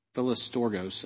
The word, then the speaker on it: Philostorgos: Philostorgos, depends on which pronunciation you got it. Philostorgos, actually, is how it's said.